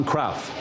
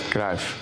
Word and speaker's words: Cruyff: No, it is Dutch football legend Johan Cruyff. – It's pronounced Cruyff.